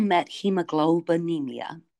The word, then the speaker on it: methemoglobinemia: It's called methemoglobinemia.